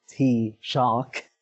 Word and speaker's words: Taoiseach: So on top of teaching me how to say the Irish word for prime minister, which I now know is Taoiseach, he also told me about this upcoming last week of campaigning for the elections and what we can expect from that.